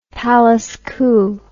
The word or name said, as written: palace coup